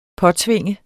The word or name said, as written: påtvinge